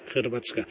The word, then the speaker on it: Hrvatska: Hrvatska.